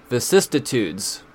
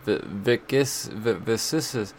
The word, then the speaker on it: vicissitudes: Vicissitudes. Nope, said it wrong. Vicissitudes.